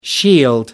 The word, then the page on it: shield: American English